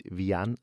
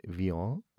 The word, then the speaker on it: Vian: Nicht Vian, sondern Vian. Ja, ist ein Franzose.